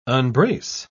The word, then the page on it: unbrace: British English